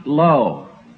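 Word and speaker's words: lo (לא): In Hebrew, the word for "not" is pronounced "lo."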